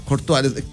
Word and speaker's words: Courtois: Courtois, ¿lo dije bien? – Sí.